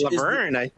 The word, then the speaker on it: Laverne: Who would have thought that this is Laverna Cox, you know, straight out from the orange. – Laverne.